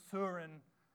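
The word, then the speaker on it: Søren: And so as a nationality, it's my obligation to mention Soren Kierkegaard because he's got an "uh," which is an O with a line through it that you pronounce "uh," Soren.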